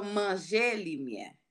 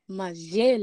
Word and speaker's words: manger le miel: So manger le miel. – Manger le miel. Okay, one more time. – So to pronounce it, it's manger. – Manger. – That translates to eat, and le miel is light.